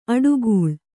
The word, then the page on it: ಅಟ್ಟುಗೂಳು: aṭṭugūḷu